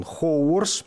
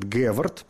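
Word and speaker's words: Haworth: Впрочем, в э, русской транскрипции иногда произносят Гевард или Ховард. Так что, если где-то встретите имя Гевард, то знаете, что Хоуворс и Геверт – это одно и то же лицо.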